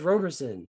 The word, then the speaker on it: Roberson: If there was a T there, I would say Robertson. I say it that way, but there's no T, so it's Roberson.